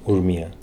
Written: Urmia

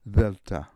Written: Delta

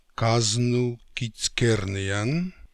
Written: Kaznookizkernian

